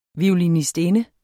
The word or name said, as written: violinistinde